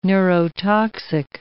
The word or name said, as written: neurotoxic